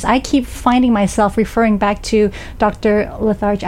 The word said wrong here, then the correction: DeLarge: I always say her name wrong. – DeLarge. – I keep thinking Lethargy. DeLarge,